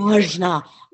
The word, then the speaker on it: Ajna: So when it comes to open Anja, I'm still probably saying Ajna. Ajna. I'm gonna say it like real Ajna.